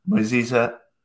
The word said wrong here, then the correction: Muniza: sorry i said it right earlier Muniza Fatel from Blue Zoo.